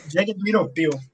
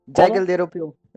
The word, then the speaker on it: Jagged Little Pill: Jagged Little Pill. – Jagged Little Pill.